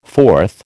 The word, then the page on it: forth: American English